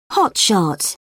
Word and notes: hotshot: British English

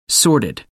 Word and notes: sorted: American English

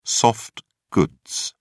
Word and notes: soft goods: British English

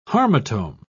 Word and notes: harmotome: British English

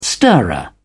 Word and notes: stirrer: British English

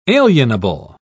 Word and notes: alienable: British English

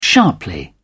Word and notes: sharply: British English

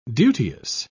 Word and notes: duteous: British English

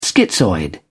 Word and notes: schizoid: British English

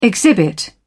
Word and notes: exhibit: British English

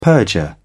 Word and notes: perjure: British English

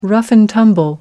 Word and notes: rough and tumble: British English

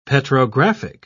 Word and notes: petrographic: British English

